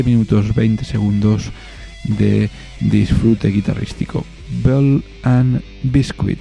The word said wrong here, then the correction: Ball and Biscuit: Ball and Biscuit, perdón, no Bell, Ball, hay que pronunciarlo bien, Ball and Biscuit de White Stripes.